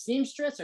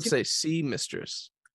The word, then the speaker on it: Seamistress: So the I don't know if it's pronounced seamstress or semi-stress, but seamistress. – Oh, seamistress. Okay, there you go.